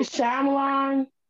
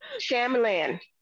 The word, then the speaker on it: Shyamalan: Night Shyamalan. – Shyamalan.